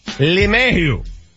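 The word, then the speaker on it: LeMahieu: You know, that guy pronounces it correctly, by the way. It's L-E-M, not L-A-M. Lemayhew.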